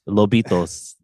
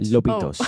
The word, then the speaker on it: Lobitos: Don't say it like that. Lobitos. – Right. – Lobitos. – Oh. Right. No, it's like baby wolves.